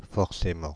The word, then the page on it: forcément: Paris; [fɔʁ.se.mɑ̃]